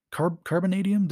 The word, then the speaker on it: Carbonadium: Carbonadium? Did I say that right? Yes.